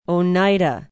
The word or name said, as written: Oneida